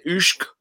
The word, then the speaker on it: Usyk: Usyk.